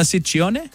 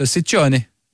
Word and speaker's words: Ciccone: Well, in Italian, it works the other way. So the double C's are hard C's and the singular C's are soft C's. – So Ciccone. – Ciccone. – Ciccone. Madonna Ciccone. God, why'd she drop that? That's good. It's Ciccone. Anyway, it's Freedom Fridays.